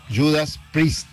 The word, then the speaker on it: Judas Priest: Se pronuncia Judas Priest. Priest.